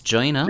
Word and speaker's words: Gina: Although, am I saying it right? Is it Gina or Juliana? – Gina. No, Gina. Yeah. – Gina. – Gina. I'm Gina Liano.